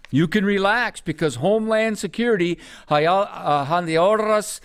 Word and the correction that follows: Mayorkas: uh, Mayorkas, I just butchered his name.